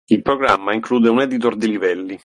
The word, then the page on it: programma: italian